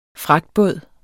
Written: fragtbåd

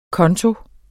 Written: KONTOEN

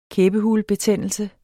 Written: kæbehulebetændelse

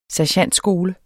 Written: SERGENTSKOLE

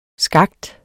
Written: skakt

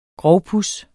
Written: grovpuds

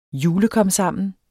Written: julekomsammen

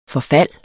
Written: forfald

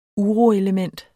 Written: uroelement

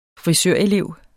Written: frisørelev

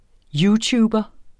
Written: youtuber

